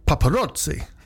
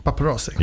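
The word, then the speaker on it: Paparozzi: And they are already being praised by Rob Paparazzi. – I like them on pizzas. Great. – Paparazzi.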